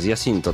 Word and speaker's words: Jacinto: I jedną z nich jest Gears of War 2, ostatni z Jacinto, z Jacinto tak naprawdę, zawsze tak było wymawiane.